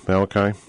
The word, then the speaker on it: Malachi: Yeah, Malachi.